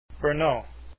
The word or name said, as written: brno